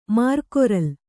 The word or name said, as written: ಮರಗೊರಲೆ